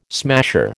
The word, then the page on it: smasher: American English